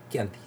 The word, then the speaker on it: Chianti: Chianti. Y se escribe C-H-I-A-N-T-I.